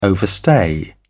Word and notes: overstay: British English

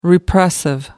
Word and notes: repressive: American English

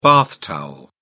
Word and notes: bath towel: British English

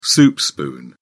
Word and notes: soup spoon: British English

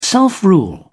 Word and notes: self-rule: British English

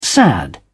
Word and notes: sad: British English

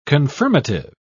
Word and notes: confirmative: British English

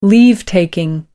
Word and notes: leave-taking: American English

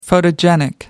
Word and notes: photogenic: American English

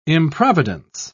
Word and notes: improvidence: British English

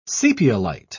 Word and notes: sepiolite: British English

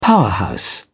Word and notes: powerhouse: British English

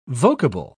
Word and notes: vocable: British English

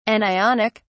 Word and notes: anionic: British English

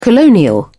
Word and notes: colonial: British English

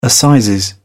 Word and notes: assizes: British English

